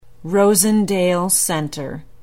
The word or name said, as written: rosendale center